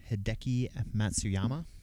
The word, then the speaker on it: Hideki Matsuyama: That is Hideki Matsuyama.